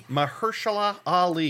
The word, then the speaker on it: Mahershala Ali: Mahershala Ali. – Mahershala Ali.